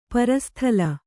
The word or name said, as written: ಪರಸ್ಥಳ